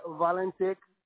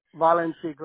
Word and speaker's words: Valentik: Valentik. – Okay, spell that for me because I have no idea. – V A L. – Okay. – E N T I K. Valentik, like Valentine's Day. Valentik.